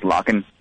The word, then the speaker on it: LOCKN: It's called LOCKN, pronounced Locken, if you will.